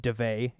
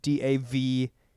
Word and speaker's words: Daveigh: I believe it's pronounced DeVay. It's DaveIgh.